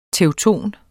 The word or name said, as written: TEUTONER